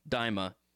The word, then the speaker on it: Daima: Dragon Ball, don't know how to say this word, going to try, Daima. D-A-I-M-A.